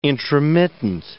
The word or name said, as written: intromittent